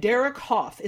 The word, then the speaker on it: Hough: Jennifer Lopez, Neo, and Derek Hough. Is that how you say his name? Is it Hough? – Yeah, Derek Hough.